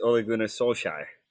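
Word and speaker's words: Ole Gunnar Solskjær: Can you pronounce it for me? – I can. It's Ole Gunnar Solskjaer.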